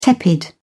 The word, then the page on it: tepid: British English